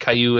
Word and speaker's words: Caillou: I mean, I don't hate Caillou any more than I hate all the other stuff my kids watch.